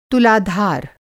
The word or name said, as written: तुलाधार